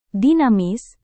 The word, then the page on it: dinamis: Indonesian